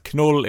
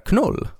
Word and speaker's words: Knull: som heter Knull. Alltså K-N-U-L-L. Uh, men det ska väl uttalas egentligen som null eller Noll eller, eller något sånt.